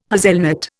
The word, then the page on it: hazelnut: Indian